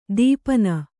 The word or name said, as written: ದೀಪನ